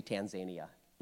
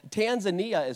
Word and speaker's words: Tanzania: I always want to say Tanzania, but that's not right, so don't say that. So Tanzania is where Martha lives in Africa, and she turns 14.